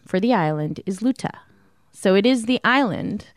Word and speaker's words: Luta: And you're an entrepreneur and you're firm is Luta Security.